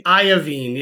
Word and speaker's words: Iovine: How do you say that man's last name? – I say Iovine. Yeah.